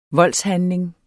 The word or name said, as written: VOLDSHANDLINGENS